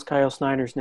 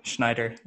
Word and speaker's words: Snyder: He also couldn't pronounce Kyle Snyder's name. – Schneider, yeah.